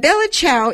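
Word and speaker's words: Bella ciao: And Bella Chow, it's pronounced Bella Chow. It means goodbye, beautiful.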